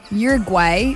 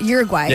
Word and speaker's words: Uruguay: Uruguay. That's right. Uruguay.